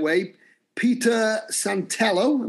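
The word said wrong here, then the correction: Santenello: am I saying that right? – Santanello. – Okay, Peter Santanello, the YouTube travel vlogger, you gave him a tour of New York's Hasidic community in a 15-episode series.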